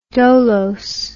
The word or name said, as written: dolose